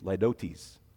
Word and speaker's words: litotes: It looks like litotes. It's laidotes.